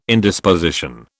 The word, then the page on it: Indisposition: British English